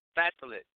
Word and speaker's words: Phthalates: Phthalates. Phthalates.